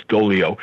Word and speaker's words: Doglio: Okay, but her name is spelled with a G, so it would be like as though it was Doglio, but the G is silent, so it's Dolio.